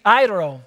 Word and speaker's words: airo: Now, to take away is actually the Greek airo. Everybody say airo. Airo. You have to kind of roll your R a little bit.